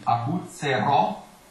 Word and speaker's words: aguzzerò: Stai parlando per esempio la vincerò, no, la guzzerò, e c'è un accento, e perciò bisogna accentare l'ultima sillaba, quindi aguzzerò, la vincerò.